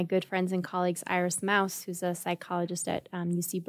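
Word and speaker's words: Mauss: She spells her name the same way that I do, and I pronounce it Moss, and she pronounces it Mouse, and I think she is correct. she's from Germany.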